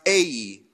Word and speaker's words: Ai: So now the, the very next battle is the battle of Ai. Ai, it's pronounced Ai.